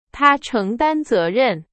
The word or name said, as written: 承担